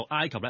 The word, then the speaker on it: 埃及: 咁读埃及呢原因係好简单嘅啫，因为我哋中文写出嚟土字边嗰个埃字呢喺粤语入面本身得一个读音嘅啫，就係尘埃个埃。咁但係点解我话埃及係应该读埃及呢，好简单，大家知道有一种东西叫氰化物㗎。係。